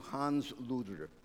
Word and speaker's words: Hans Luder: Luther was born in 1483, November the 10th, and he was born to Hans Luder. L-U-D-E-R is the way it would have been pronounced in German. Hans Luder.